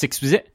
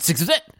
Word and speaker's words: SXSW: South by Southwest, Sixxvzit, as we call it here in Austin. We call it Sixxvzit. – You just say it like a word.